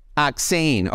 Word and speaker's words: Achtzehn: You said it was it was is it the number eight in German? So say it for us. – It's number eight. Saying. Achtzehn.